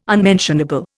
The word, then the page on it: unmentionable: Indian